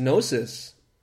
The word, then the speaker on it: gnosis: Because gnosis, if we examine the term, in Greek it's a silent G,